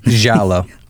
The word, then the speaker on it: giallo: So I guess the intent is this is supposed to be, is it a giallo film? Is that how you say it? – Giallo. – Giallo? – Yeah, well, my voice kind of cracked. Giallo.